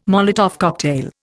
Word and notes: Molotov cocktail: Indian

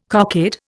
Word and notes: cockade: Indian

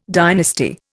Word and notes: dynasty: Indian

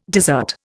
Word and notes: dessert: Indian

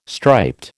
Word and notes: striped: American English

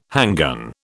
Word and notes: handgun: British English

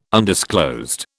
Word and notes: undisclosed: British English

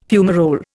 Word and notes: fumarole: Indian

